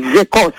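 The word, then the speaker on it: Sécos: It's called Zecos, S-E-C-O-S, with an accent on the E, Zecos.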